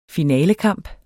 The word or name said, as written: finalekamp